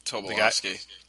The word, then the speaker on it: Tobolowsky: Tobolowsky. – Tobolowsky.